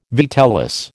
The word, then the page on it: vitellus: American English